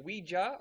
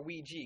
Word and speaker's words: Ouija: Is it Ouija or Ouija? Because I've heard it different ways. – It is pronounced both ways, and all through pre-production, we would pronounce it all these different ways,